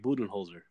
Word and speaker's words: Budenholzer: Yeah, Mike Budenholzer.